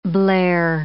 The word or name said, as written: Blair